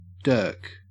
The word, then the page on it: dirk: Australian English